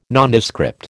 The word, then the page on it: Nondescript: American English